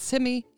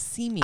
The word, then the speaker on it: Simi: On to Simi Valley. – It's just a great way to say it. We say it the cool way now. It is not Simi. It is Simi.